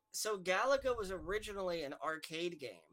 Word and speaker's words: Galaga: Galaga. G-A-L-A-G-A. Yes. Galaga. – G-A-L-A-G-A.